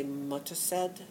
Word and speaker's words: Mothershed: But in England, they don't pronounce the H's when they say Mothershed. They say Mutterced. So that became Modisette.